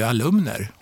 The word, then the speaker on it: alumn: 2019, Karlstad universitet utnämnd till årets, uttalas det alumn? Alumn, ja precis.